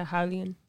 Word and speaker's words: Raulian: Sean O'Malley versus Raulian. Is that how you say his name? – Haulian Paiva? – Yeah, Haulian.